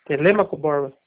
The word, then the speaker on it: Telêmaco Borba: Certo, o senhor trabalha aí na cidade de Telemaco Borba, é isso? – Telêmaco Borba. – Telemaco? Eu falei errado, perdão.